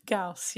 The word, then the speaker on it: Gauss: Gauss, yeah.